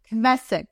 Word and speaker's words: Kvesic: Kvesic.